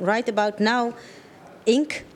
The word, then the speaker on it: Right About Now Inc.: Programmeur muziek en mede-artistiek leider van Right About Now Inc. – Ja. – Zo zeg ik het goed, toch? – Je zegt het goed, ja.